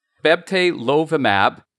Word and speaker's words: bebtelovimab: Yeah, so it's bebtelovimab, and we think it works.